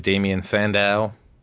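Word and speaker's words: Damien Sandow: WWE Superstars Damian Sandow. Did I say that right? – Yes.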